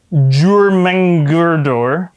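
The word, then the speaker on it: Jörmungandr: But then he also has a kid that is a serpent named Jurmengurdor. – Yeah, I believe that's correct pronunciation.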